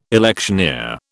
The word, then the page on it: electioneer: British English